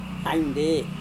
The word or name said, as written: ainde